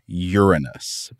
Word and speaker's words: Uranus: If you listen to astronomers and planetary scientists talk about this planet, most often you will hear them pronounce it Uranus, basically the word urine and then the word us.